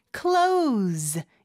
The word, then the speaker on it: close: why don't you 혹은 why don't you 한 다음에 close 여기서 close는 S가 Z 발음입니다. 그거 중요하죠.